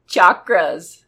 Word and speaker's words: chakra: Yeah, because we're going to talk about chakras. – Chakras or chakras. – Everybody says chakra, but it's spelled a CH. Chakra. – I think in the Sanskrit it is chakra.